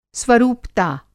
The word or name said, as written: स्वरूपता